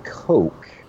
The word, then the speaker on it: Koch: Of mine, and it's pronounced Coke. – Coca-Cola? – I think it's a is that a German pronunciation?